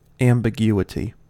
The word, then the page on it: ambiguity: American English